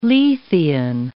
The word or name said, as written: Lethean